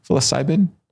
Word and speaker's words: psilocybin: psilocybin. Is that how you say it? Psilocybin.